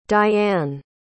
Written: diann

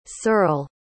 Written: searle

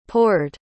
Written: pored

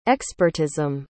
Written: expertism

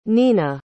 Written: nena

